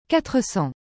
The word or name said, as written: 400